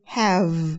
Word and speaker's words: have: người việt mình hay sai ở chỗ là mình hay thêm chữ ơ vào hoặc là vờ vờ ví dụ như have thì người việt mình lại đọc là have have thì nó không phải chữ ơ ở thêm như vậy nó chỉ là một âm rung ra ở chữ v như số 5 sẽ là five v, v.